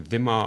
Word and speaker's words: Dhimma: Wie Dhimma ausgesprochen, wie das englische TH am Anfang.